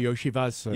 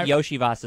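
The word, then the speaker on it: Iosivas: And Yoshivas or something like that, that Andre Yoshivas. – I was just going to look up the pronunciation. – Anyway, I think Yoshivas is correct.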